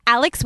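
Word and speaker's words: Alix: Instagram's really the only one I interact with right now, is Alex with an Oi. And my parents spelled my name wrong, so it's A-L-I-X with an Oi.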